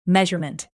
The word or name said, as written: measurement